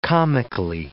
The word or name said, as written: comically